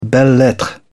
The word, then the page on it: belles-lettres: British English